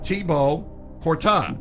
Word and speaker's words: Thibaut Courtois: All right, Real Madrid goalkeeper Thibaut Courtois, pronounced Thibaut Courtois.